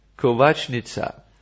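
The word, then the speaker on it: kovačnica: How do we say it in Croatian, please? Kovacnica, yes. And he will bring you into his Kovacnica.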